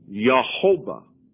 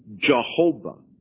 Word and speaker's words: Yehovah: And he pronounced it Yehovah, the soft J sound. If he had the harder J sound, it would be Jehovah.